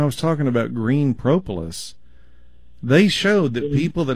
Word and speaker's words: propolis: P-R-O-P-O-L-I-S. Propolis. – Okay. – It's almost like the word Polish with a pro on the front of it.